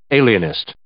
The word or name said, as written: alienist